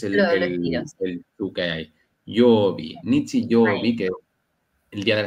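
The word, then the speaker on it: Nichi, Yobi (日曜日): Domingo es día Sol, ¿y cómo se pronunciaría entonces? – Sería Nichi, y le colocas el Yobi. Yobi sería día de la semana, ¿vale? ¿Cómo decir Yobi? – Nichi, Yobi.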